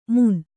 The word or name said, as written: ಮುನ್